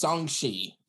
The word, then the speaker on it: Shang-Chi: Everyone keeps pronouncing Shang-Chi as Shang-Chi. It's Shang-Chi.